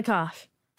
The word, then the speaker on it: Kopf: Corinna Kopf. – Kopf. – Yeah. – Is that how you say it? You don't even say the P.